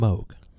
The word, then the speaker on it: Moog: It's pronounced Moog. – Moog. – Okay. Yeah. Moog. – Yeah. – Yes. – Moog bass. – I learned that from last time. – Moog bass. Not Moog. Moog.